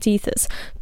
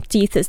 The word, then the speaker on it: Tethys: Among these daughters was Thetis, which is a different person from Tethys the titan and mother of Doris, who I just mentioned. It's written differently, but pronounced almost the same.